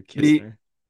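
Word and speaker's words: Knizner: Andrew Nisner. – Andrew Kisner. – We're going to, because it's spelled like Nisner, so I always say that.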